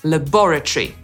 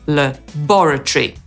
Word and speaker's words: laboratory: Brytyjczyk powie laboratory, czyli zaakcentuje drugą sylabę, bo laboratory. – Kurchatov Institute Laboratory 4. – Marina Gozenskaya. It's Alana Homiuk from Minsk. – Natomiast Amerykanin zaakcentuje pierwszą sylabę i powie laboratory.